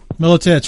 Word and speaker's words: Miletich: My guest tonight is Pat Miletich. There, I almost, right? Did I say that right? – Miletich, sorry.